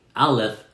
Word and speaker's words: Aleph: Can you say Aleph? I spell it A-L-E-P-H, Aleph.